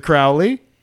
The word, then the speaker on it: Aleister Crowley: He said, they pronounce my name Crowley because they wish to treat me foully. But my friends say Crowley for they know I am holy.